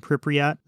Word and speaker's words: Pripyat: Ukraine has a bunch of radiation sensors, particularly in the Chernobyl and Pripyat. Did I say that right? – I think so.